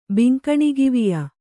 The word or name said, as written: ಬಿಂಕಣಗಿವಿಯ